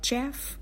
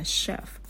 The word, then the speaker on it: chef: Hay là từ chef này này, CH mà người ta không đọc là chef, mà người ta đọc là chef.